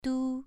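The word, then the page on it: du: tone 2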